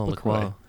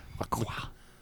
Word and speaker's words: La Croix: When you pronounce it right, you don't say anything. No words actually come out. You just pose and people know like, oh, it's La Croix. – Yeah. – La Croix.